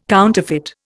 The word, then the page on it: counterfeit: Indian